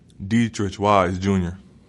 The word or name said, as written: Deatrich Wise